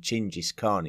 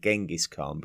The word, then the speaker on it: Genghis Khan: I think when we were talking about the pronunciation, but uh, using the pronunciation Chingis Khan instead of Genghis Khan because it was uh, much more in line with uh, the Mongolian uh, version of his name.